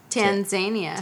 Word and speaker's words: Tanzania: How do you pronounce it? – Tanzania. – Okay.